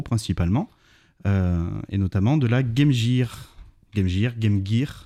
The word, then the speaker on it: Game Gear: La prononciation exacte c'est Game Gear en anglais.